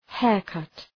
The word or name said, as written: haircut